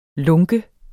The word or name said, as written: lunken